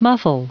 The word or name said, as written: muffle